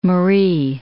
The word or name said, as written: Marie